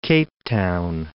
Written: Cape Town

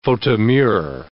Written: faute de mieux

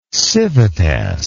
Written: civitas

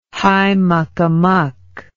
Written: high muckamuck

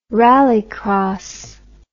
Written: rallycross

